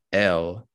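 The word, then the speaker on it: iel: Still, the furor provoked by a prominent dictionary's inclusion of the pronoun EL. Natalie, you want to correct the pronunciation? – No, that's pretty much right. Just with an American accent.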